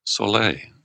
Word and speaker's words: Soleil: Yes. Soleil. Okay. Silent L on the end.